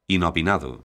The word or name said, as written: inopinado